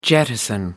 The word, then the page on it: jettison: American English